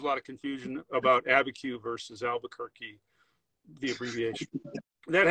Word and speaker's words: Abiquiu: And people get all, and it, so when you say it correctly, it's Abiquiu. So it really sounds like, you're just saying the letters ABQ.